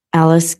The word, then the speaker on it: Alice: Alice Gilbert, A-L-I-C-E. G-I-L-B-E-R-T.